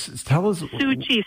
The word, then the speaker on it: Tzu Chi: And I know you were so, so excited about sushi. Did I say that right? But maybe Su-chi, you could repeat that and tell us. – Su-chi. So it's spelled T-Z-U-C-H-I.